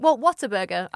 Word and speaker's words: Whataburger: Whataburger. I would say what a burger. – Yeah. – And in Texas, they were like, Whataburger.